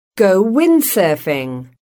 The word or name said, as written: go windsurfing